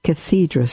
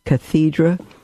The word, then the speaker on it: cathedra: Since it is a dogma defined by the Pope rather than by an ecumenical council, for example, it is also an ex cathedra statement. That is, one delivered from the chair of Peter. Ex out of cathedra, or cathedra, could be pronounced either way, is the chair.